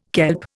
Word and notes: kelp: Indian

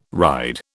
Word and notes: ride: British English

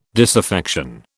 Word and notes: disaffection: British English

